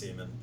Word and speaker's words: Semien: You know how to pronounce his name, Ben. It's Marcus Semien.